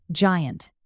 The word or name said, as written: giant